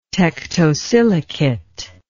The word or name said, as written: tektosilicate